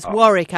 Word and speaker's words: Warwick: It's Warwick, actually, it's pronounced. We British pronounce things in funny ways.